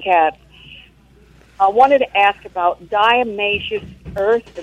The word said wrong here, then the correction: diatomaceous: Diatomaceous. It has a T.